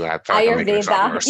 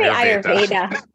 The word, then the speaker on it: Ayurveda: Ayurveda. You can say Ayurveda. Ayurveda also works.